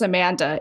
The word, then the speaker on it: Amanda: I mean, to the point that sadly, because my name is Amanda, it rhymes with panda.